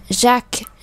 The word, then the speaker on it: Jacques: Jacques Frère Frère các bạn để ý âm frère frère frère Jacques Jacques Jacques không phải ắc mà Jacques